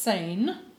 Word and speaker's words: Sijnn: But probably the spelling from the Dutch influence, S-I-J-N-N. Pronounced Seine.